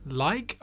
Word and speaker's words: Liak: Track called Rose Drops by an artist called Like, I think it's pronounced L-I-A-K.